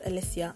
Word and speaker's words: Alicia: And it's pronounced Alicia, not Alicia.